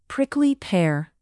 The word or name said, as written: prickly pear